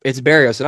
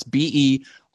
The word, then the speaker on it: Berrios: It's Berrios. It honestly kind of sounds just like it's spelled just like it sounds. It's Berrios.